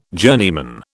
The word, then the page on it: journeyman: British English